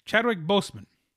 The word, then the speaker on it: Chadwick Boseman: Chadwick Boseman.